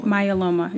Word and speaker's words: myeloma: Myeloma. – Myeloma, yes. You are in great company because pretty much everybody I've ever encountered initially mispronounces it.